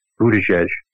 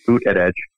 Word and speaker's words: Buttigieg: At least one person who was interviewed for the poll, they didn't list all the candidates, and they left off Pete Buttigieg, since I keep mispronouncing it as Boot at Edge.